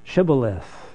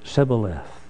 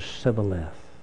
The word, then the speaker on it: Shibboleth: to pronounce a certain word, Shibboleth. And because the people had a speech infirmity, if you like, they just said Shibboleth. It wasn't Shibboleth, it was Shibboleth.